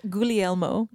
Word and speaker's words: Guglielmo: And then I practiced Guglielmo. But yeah, we have a running joke on the podcast that we cannot pronounce anything for the life of us. And we keep choosing things. – Especially French things. – I'm pretty sure you just nailed that.